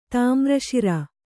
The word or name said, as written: ತಾಮ್ರಶಿರ